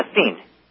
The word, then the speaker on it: Essene: Yeah, Essene. E-S-S-E-N-E.